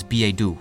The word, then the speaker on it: billet-doux: The word still has the same meaning in modern French, and the French pronunciation for billet-doux is billet-doux.